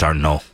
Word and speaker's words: Arnault: I think you said his last name wrong. I think it's Arnault.